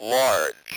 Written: large

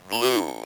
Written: blue

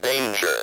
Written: danger

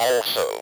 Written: also

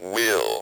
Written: will

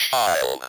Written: child

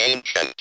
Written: ancient

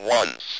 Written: once

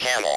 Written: camel

